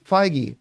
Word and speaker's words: Feige: And then he pulled this little BS thing where he goes, honestly, I'm not, how do you pronounce his last name? Feige? And the guy said, Feige.